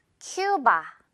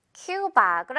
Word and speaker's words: Cuba: 우리가 하도 우 발음으로 알고 있던 단어들이다 보니까. 그래서 연습을 많이 하는 게 정말 중요하겠어요. 자, 예문들로 넘어가 볼게요. 그들은 큐바에 갔대. 자, 이렇게 한국말 자체를 선생님이 좀 영어 발음으로 해줄게요. 익숙해질 수 있도록. 자, 예문 갑니다. They went to Cuba.